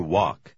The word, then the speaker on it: walk: Сейчас давайте посмотрим, как произносится буква A после W или перед L. Прочитайте номер 10. В последнем слове буква L немая. – I like to walk.